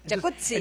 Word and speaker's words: jacuzzi: Jacuzzi. – Entonces deberíamos pronunciar el jacuzzi. – Exacto. – Como pizza. De... como Rampazzo, sí. Como Claudio Rampazzo.